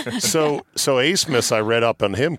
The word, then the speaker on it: Abmas: A-B-M-A-S, pronounced Asemus.